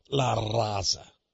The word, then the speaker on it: La Raza: I would need to pronounce it, you know, La Raza.